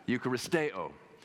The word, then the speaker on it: Eucharisteo: All right, so we're going to put the emphasis on the right syllable when you're talking about it. Eucharisteo.